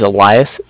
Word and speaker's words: Elias: The name's Elias, E-L-I-A-S.